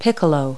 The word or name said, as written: piccolo